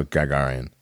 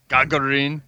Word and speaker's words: Gagarin: It's Russian, so Gagarin. – Gagarin. – Gagarin. Yuri Gagarin. It doesn't sound... Yuri Gagarin.